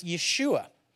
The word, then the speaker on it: Yeshua: It's Joshua, but they don't pronounce the J, so it's Yeshua.